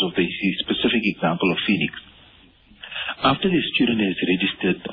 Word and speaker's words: Feenix: I'll spell it as F-E-E-N-I-X, Phoenix.